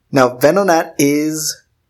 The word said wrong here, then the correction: Venonat: Why did I say it like that? Venonat.